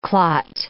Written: clot

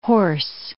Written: hoarse

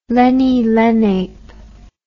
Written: leni-lenape

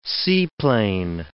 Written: seaplane

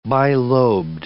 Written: bilobed

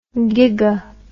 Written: giga

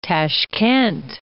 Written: Tashkent

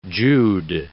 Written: Jude

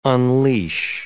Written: unleash

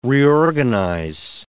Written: reorganize

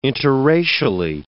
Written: interracially

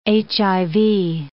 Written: HIV

